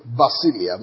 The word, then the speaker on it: basileia: One of them is basileia. I mentioned that earlier. B-A-S-I-L-E-I-A, kingdom.